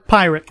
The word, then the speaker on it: Pyrrhic: That is the definition of a Pyrrhic victory. Did I say that right? – Pyrrhic.